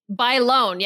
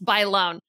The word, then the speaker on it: Bailon: It was, oh my God, I always mispronounce her name. – Adrian. – Adrian Bailon. Bailon, yes, Bailon.